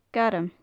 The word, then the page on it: gairm: gaelic